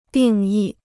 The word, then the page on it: 定义: dìng yì